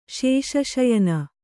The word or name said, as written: ಶೇಷಶಯನ